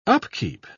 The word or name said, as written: upkeep